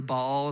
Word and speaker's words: Baal: Altar to Baal, or really Baal is how you say that, and a pole to Asherah.